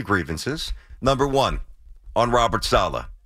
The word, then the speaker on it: Saleh: Robert Salah, the worst, worst. Right. – He's going into his third year. Can we get his name right? It's Saleh. I mean, let's at least get the guy's name right.